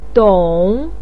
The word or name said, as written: dong3